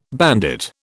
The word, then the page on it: bandit: British English